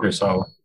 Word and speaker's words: Kurosawa: Just because The Most Beautiful by Akira Kurosaki. I said it wrong. – Kurosawa. – Yeah, Kurosawa, thank you.